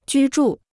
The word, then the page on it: 居住: jū zhù